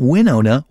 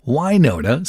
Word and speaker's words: Wynonna: No, not Winona. Wynonna. Sorry, Minnesotans. That's how that's pronounced.